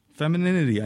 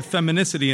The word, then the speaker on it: femininity: Femininity. I didn't even know that's how you pronounce it. I said feminicity, and it corrected me. It said, no, femininity.